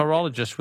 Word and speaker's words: pterologist: A pterologist with a silent P.